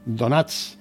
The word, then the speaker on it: Donats: En Parforana, inquietud entre los donants de Gracia. No lo he pronunciado bien, sobre una N. Donats de Gracia por su futuro en el santuario.